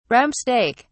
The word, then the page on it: rump steak: British English